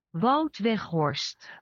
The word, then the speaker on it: Wout Weghorst: That's how I would pronounce it. But here's how it is in Dutch. – Wout Weghorst. – Wout? – Wout Weghorst. – Wout Weghorst. – Weghorst. There's a phlegmy in the GH.